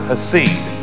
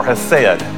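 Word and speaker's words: chesed: Now, that word mercy is a uh, Hebrew word, hasid, um, or hased. Some people pronounce it either way.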